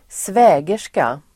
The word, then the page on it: svägerska: [²sv'ä:ger_ska]